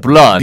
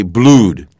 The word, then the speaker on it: blood: B-L-O-O-D. You know, Jake, many of my friends sometimes say blued. Blued is not the correct pronunciation. – No, no, no, not at all. – It looks like that. You think food, F-O-O-D, so it's the same oo sound. No, no, no. This is an sound. So B-L-O-O-D is blood.